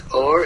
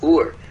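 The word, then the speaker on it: or (אור): Is sometimes pronounced or and sometimes pronounced ur. When you pronounce it or, it means light. When you pronounce it ur, aleph vav resh ur, it means a fire.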